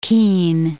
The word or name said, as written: Keene